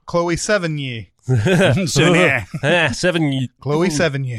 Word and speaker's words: Sevigny: It took until she got nominated for an Oscar for me to actually hear that name pronounced. – I thought it was Savini. – I did too. That's what I said. That's what I would say.